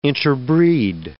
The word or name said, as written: interbreed